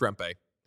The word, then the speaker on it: Rempe: It's Matt Rempe? – Yeah. – It's everybody says Matt Rempe. – Rempe. – Where'd you hear that? The fucking pronunciation guide that's wrong all the time? – This isn't going to help me, but Biz said it. And I was like, oh my God. And that's your source? – And no, and then I was like, let me hit the pronunciation guide. It's Rempe. Always was Rempe.